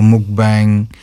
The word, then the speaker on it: mukbang: So, with Mama's Taste itself, like, as the brand itself, are you going to maybe do, like, a mukbang, if that's how they pronounce it? – Yes, it is, right? That's correct.